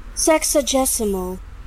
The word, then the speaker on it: sexagesimal: Sexagesimal. – Sexagesimal. – Oh, sexagesimal. – Nice. Sexagesimal. So that is. – Oh. – Shut up. – What a nice word. – Sexagesimal.